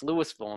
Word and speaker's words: Louisville: But here's the thing: like, if you're, what happened with Louisville is in Kentucky is that eventually people kind of dropped a syllable. If you were spelling it out and saying it properly, it would be Louisville or Louisville, because you know it's after like King Louis in France. Okay, it's not Louis. It's not named after Louis.